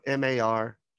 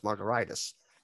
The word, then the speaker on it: Margaritis: We pronounce it like a cocktail, but it's actually should be pronounced Margaritis. But that's like hepatitis, gingivitis, laryngitis. So we prefer to be on the alcohol side versus the inflammation side.